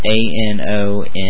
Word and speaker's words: Anonym.OS: But the live CD, it's called, I'm going to pronounce it, it's called Anonymous. It's ANONYM.OS, like for Anonym OS.